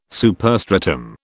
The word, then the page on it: superstratum: British English